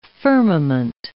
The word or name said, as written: firmament